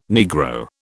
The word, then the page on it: Negro: British English